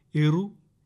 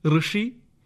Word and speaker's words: ഋ: ഇറു, ഇറു, ഇറോ, ഇർ എന്നിങ്ങനെ തെറ്റായി ഈ അക്ഷരത്തെ ഉച്ചരിക്കാറുണ്ട്. എന്നാൽ ശരിയായ ഉച്ചാരണം ഋ എന്നതാണ്.